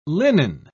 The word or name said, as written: linen